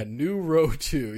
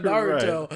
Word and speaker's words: Naruto: Yeah, Nuroto. Yeah, like, yeah, you can tell he's talking about Naruto.